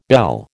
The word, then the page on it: belle: American English